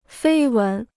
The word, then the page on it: 绯闻: fēi wén